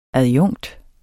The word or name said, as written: adjunkt